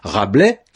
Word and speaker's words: Rabelais: Rabelais, qui se termine par un S qu'on ne prononce pas, donne rabelaisien.